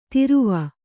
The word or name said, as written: ತೊರವಿ